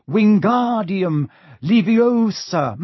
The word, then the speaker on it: Wingardium Leviosa: "Wingardium Leviosa," he shouted, waving his long arms like a windmill. – "You're saying it wrong," Harry heard Hermione snap. "It's Wingardium Leviosa. Make the gar nice and long."